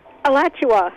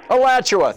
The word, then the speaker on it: Alachua: Alachua. – Alachua.